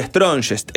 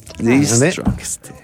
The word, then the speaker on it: Strongest: no es Strongest. – Pronunciado. – Dijo Strongest. – Strongest. – The Strongest.